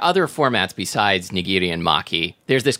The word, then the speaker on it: maki: Maki.